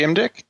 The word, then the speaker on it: Imdieke: Mine is my name, which is Corey Imdick. And my last name is spelled I-M-D-I-E-K-E.